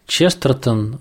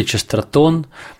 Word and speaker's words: Честертон: А как вам кажется, вот вы упомянули, что Честертон или Честертон, тут, мне кажется, можно по-разному ставить ударение, вот реагировал на реалии своего времени.